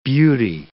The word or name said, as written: beauty